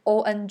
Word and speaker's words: Ong: Om Namo Guru Dev Namo，他们一开始要跟老师调频的那个咒，呃，嗯嗯嗯，Om 算是哦，嗯、呃，在在梵文里面，Om 是 A U M 的发音嘛，A U M Om，但是 Om Namo Guru Dev Namo 它是 O N G，它是 On，嗯嗯嗯，然后其实有人问那个 Yogi Bhajan，他还在世的时候，有人问他说这两个有什么不同？Om 跟 On 有什么不同？